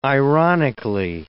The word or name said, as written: ironically